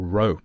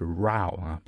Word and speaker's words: row: Row, 注意这个词啊，不读 row, OK? 它正确的发音是 row, 表示分歧。